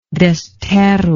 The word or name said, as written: Desterro